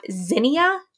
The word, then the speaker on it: Zinnia: She was mentioning it in one of her recent YouTube videos, and it is the app Zinnia, I believe is how it's pronounced. It's Z-I-N-N-I-A.